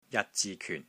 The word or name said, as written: JAT6 ZI6 KYUN4